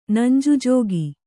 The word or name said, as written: ನಂಜುಜೋಗಿ